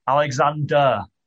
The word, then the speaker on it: Alexander: Olly, how do you say his last name? Alexandra? – Alexander. – Alexander, Olly Alexander.